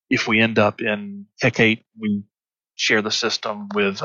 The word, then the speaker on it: Hecate: We are probably going to a system called, I'm going to pronounce it, Hecate, H-E-C-A-T-E.